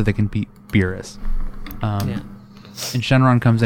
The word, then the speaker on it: Shenron: And it is pronounced Shenron, right? Shenron? – Yeah.